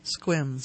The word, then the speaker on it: Sequim: Such is the case with a gentleman in, well, maybe a lady, with a person who lives in Sequim, is that how you pronounce it? – Squim. – Squim, Washington. – Yes, Squim.